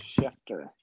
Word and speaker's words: Schefter: Schefter.